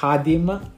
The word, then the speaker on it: Khadim: Allora, innanzitutto ciao a tutti, ciao Mo, ciao Maghet, mi chiamo Kadim Sek, la pronuncia esatta sarebbe Hadim, visto che abbiamo accennato un po' al discorso prima.